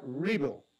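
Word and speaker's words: REvil: Bill, o mejor dicho, en la palabra, en el término sería RIBO, RIBO, pero se escribe R-Evil o R-Evil.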